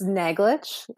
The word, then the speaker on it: Naglich: It's Naglich. It's, it, it actually is very similar to how it's spelled.